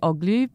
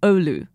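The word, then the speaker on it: Oğlu: So the end of my last name, I always say Oglu, but it should be like Olu. And so it's the silent G, I think, is a lot of what always catches my ear.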